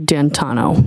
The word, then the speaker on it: D'Antuono: And when you went outside, you learned that he had come and his friend, is it Ricky D'Antuono? How do you pronounce the name? – It's Ricky D'Antuono.